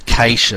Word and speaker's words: cache: That is spelt C-A-C-H-E, but it's pronounced cache. Yeah, it sounds like cache.